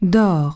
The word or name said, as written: dors